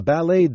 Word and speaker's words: ballet: A ballet dancer，芭蕾舞演员。 – A ballet dancer。 – Ballet 的拼法是 B-A-L-L-E-T，请注意，这是一个来自法文的外来词，结尾的 t 不发音。